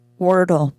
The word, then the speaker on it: Wordle: And it's called, I don't even know how to pronounce it, W-I-R-D-L-E. How would you pronounce that? – Wordle. – What? – It's pronounced exactly the same, like bird, word, Wordle.